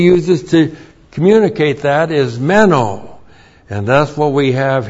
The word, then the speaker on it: meno: But there's another word that John uses over and over, meno. M-E-N, long O.